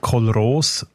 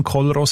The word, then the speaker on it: Kollros: Kolros, K-O-L-L-R-O-S. – Genau, ich denke, vielleicht muss man eher Kolros aussprechen als Kolros, weil es nur ES hat.